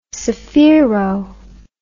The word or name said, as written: sphero